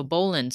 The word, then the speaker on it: bowline: All right, so the first knot here we are going to get into is the bowline, or bowline, spelled bowline, but not pronounced bowline. So bowline. So what does the bowline knot do for us?